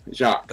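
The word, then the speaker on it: Jacques: My name has a silent Q-U-E-S. Basically, all the pronunciation is J-A-C. Jocks.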